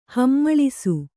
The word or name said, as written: ಹಮ್ಮಳಿಸು